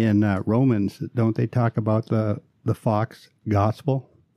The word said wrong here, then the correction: faux: My apologies to the French speaking people out there. It's pronounced faux.